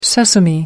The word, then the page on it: sesame: American English